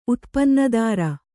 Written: ಉತ್ಪನ್ನದಾರ

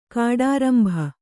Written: ಕಾಡಾರಂಬ

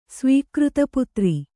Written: ಸ್ವೀಕೃತಪುತ್ರ

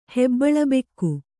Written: ಹೆಬ್ಬಳಬೆಕ್ಕು

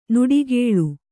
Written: ನುಡಿಗೇಳ್

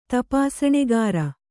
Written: ತಪಾಸಣಿಗಾರ

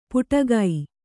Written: ಪುಟಗೈ

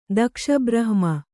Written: ದಕ್ಷಬ್ರಹ್ಮ

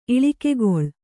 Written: ಇಳಿಕೆಗೊಳ್

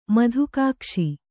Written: ಮಧುಕಾಕ್ಷಿ

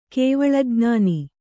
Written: ಕೇವಲಜ್ಞಾನ

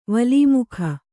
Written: ವಲ್ಮೀಕ

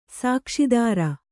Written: ಸುಕ್ಷೇತ್ರ

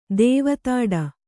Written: ದೇವತಾಡ

